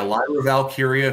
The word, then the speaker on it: Lyra Valkyria: You got, is it Lyra? Am I saying that right? Lyra Valkyria. – That's right. Yeah, Lyra Valkyria, who is the women's intercontinental champion.